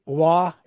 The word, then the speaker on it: Waugh: There's Billy Wah. Is that how you pronounce his last name? – Yeah, that's correct.